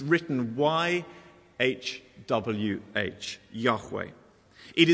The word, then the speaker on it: Yahweh: The Hebrew is pronounced something like Yahweh. I'm not a Hebrew scholar, but that is how it's pronounced.